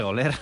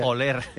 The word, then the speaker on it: oler: Es que empieza a goler todo el vagón. – Sí, sí, qué horroroso, hombre, por favor. – Bueno, pero pueden hablar bien, no se dice goler. – Sí, bueno, déjalo. – Se dice oler.